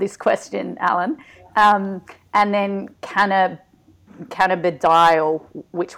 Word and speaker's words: cannabidiol: So get a bit of basics with, I'm going to have trouble pronouncing these words as well, cannabidiol.